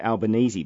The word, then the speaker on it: Albanese: Karen is also the author of a book, Albanese, Telling It Straight. Karen, welcome to the program. – Thank you very much, Bill. – Now, Karen, I said the name there, Albanese, but there is some uncertainty about how to pronounce Anthony Albanese's name, isn't there? – That's right, yeah.